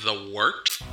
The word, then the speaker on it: Wort: It is The Wort. Not warts, not forts, not short. The Wort.